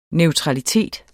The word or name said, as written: neutralitet